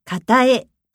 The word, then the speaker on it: 片え: 片方と書いて片えと読みます。